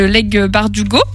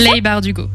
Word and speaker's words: Leigh Bardugo: Léonie nous a ensuite présenté Six of Crows de Leigh Bardugo. Du coup, c'est Leigh Bardugo. – Ok, je le prononce très mal.